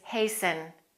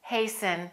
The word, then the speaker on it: hasten: The next word is hasten, hasten, and that T is usually silent. Most Americans don't pronounce the T.